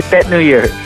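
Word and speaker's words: Tết: Thanks for taking the time to talk to us about your cuisine, and happy Tet New Year. Did I say that right? – Correct. Happy New Year. Happy Tet New Year.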